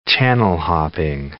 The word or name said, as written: channel-hopping